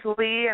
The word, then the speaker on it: Lea: My name is Audrey, and then my middle name is Lea, and it's spelled L-E-A, and my last name is Curry.